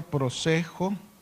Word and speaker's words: prosejo: Esta palabra en el griego que viene de una palabra griega que es prosejo, así como se pronuncia prosejo con J al final, y significa prestar atención, aplicarse y prepararse en privado.